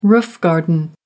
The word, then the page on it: roof garden: American English